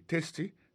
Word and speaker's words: tasty: Food, F-O-O-D, ça veut dire manger. Tasty, T-A-S. T-Y, ça veut dire goût, goût dans bouche. T-A-S-T-Y, vous prononcez le testi, ça veut dire goût dans bouche.